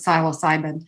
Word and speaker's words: psilocybin: And I'm talking, for the record, legal mushroom use, psilocybin or psilocybin. People pronounce it different ways. I pronounce it psilocybin.